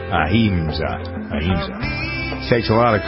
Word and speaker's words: Ahimsa: Victoria Ahimsa.